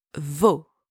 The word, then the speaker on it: vos: You use vos. And same, don't pronounce the S at the end.